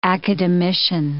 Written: academician

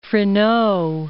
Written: Freneau

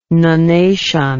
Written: nunnation

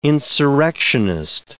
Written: insurrectionist

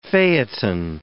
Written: phaeton

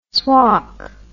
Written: swalk

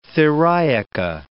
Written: theriaca

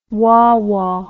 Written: wawa

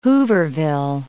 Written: Hooverville

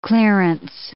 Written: clearance

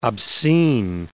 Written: obscene

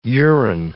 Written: urine

